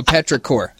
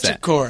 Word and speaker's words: petrichor: Yeah, so petrichor is the way we pronounce petrichor.